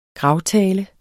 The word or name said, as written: GRAVTALE